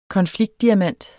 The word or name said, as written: konfliktdiamant